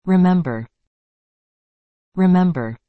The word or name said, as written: remember